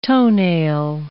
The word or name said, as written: toenail